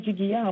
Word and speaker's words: Jigiya: Dites-moi si je le prononce bien, Jigia. En bambara. – Très bien, merci beaucoup. C'est bien Jigiya en Bambara, parce que là, on est parti au Mali pour la première Fashion Week.